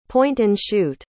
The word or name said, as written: point-and-shoot